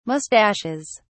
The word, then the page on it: moustaches: British English